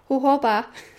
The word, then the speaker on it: jojoba: I'm not sure if I'm saying that right, because I said yesterday, "How do you say jojoba oil?" And they both laughed and went, "Jojoba," and I went, "I still don't think I'm saying that right."